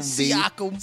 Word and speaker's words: Siakam: Siakam.